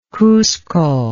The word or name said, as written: cusco